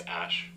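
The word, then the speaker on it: Æ: Ash. – go back. – I mean, it's just X, the letter X. And then the AE is, like, pronounced Ash.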